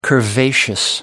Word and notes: curvaceous: American English

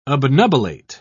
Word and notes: obnubilate: British English

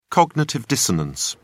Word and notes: cognitive dissonance: British English

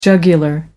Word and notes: jugular vein: British English

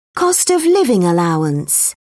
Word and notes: cost-of-living allowance: British English

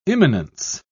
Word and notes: immanence: British English

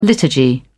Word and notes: liturgy: British English

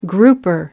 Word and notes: grouper: American English

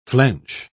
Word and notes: flench: British English

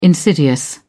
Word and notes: insidious: British English

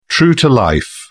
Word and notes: true-to-life: British English

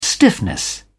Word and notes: stiffness: British English

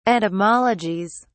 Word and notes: etymologies: British English

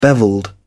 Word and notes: bevelled: British English

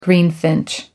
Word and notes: greenfinch: American English

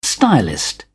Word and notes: stylist: British English

